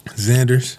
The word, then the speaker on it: Zanders: Zanders.